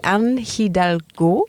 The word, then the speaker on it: Anne Hidalgo: Anne Hidalgo? – Perfect.